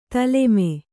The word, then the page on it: ತಾಲೀಮ: tālīmu